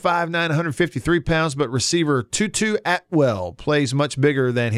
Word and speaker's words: Tutu: His name is Tutu Atwell. Wow. His first name is spelled T-U-T-U. – Like the ballerina thing.